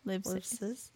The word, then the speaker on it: Livesay's: Livesay's. Yeah, it's like live and then says.